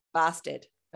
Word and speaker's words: Barstad: Barstead.